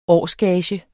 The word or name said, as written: årsgage